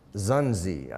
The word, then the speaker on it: Xunzi: M-E-N-C-I-U-S, and Xunzi, I guess is how you say that, X-U-N-Z-I. I guess that's how you pronounce it, I don't know.